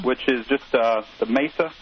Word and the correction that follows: masa: I know I'm not pronouncing that properly. – I think it's masa. – Masa. – Masa. That's the Spanish pronunciation, isn't it? – Right.